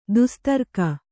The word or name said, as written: ದುಸ್ತರ್ಕ